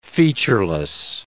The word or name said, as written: featureless